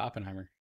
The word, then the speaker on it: Oppenheimer: Yeah, Oppenheimer.